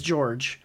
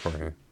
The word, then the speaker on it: Jorge: I don't know if it's George, but it's spelled like that. – I'm pretty sure it's Jorge.